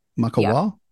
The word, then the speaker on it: Makawao: Uh, and Makawao, Makawao.